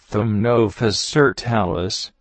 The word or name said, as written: Toots